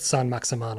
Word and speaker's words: Saint-Maximin: So it's Saint hyphen Maximin, but Saint-Maximin, I think is how you say it.